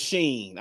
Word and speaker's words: Shein: Number five Shein. Okay. – I hate the way they pronounce that shit. It's Shein. I don't care what it's Shein. I Googled it, bro.